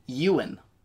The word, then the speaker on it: Ewan: It's apparently pronounced Ewan.